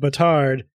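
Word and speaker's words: bâtard: They're the Butarians instead of the Batarians because the French word batard is uh, which is, is pronounced batar means bastard.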